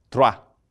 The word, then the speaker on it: Troyes: Então, Honório II, o Papa, reuniu um concílio em Troyes, atenção agora para a pronúncia do francês, né? Esse é o nome da cidade, Troyes. Eu sei que, para quem não sabe francês, né? Ou seja, francês é assim, ele escreve umas 15 letras e pronuncia duas, entendeu?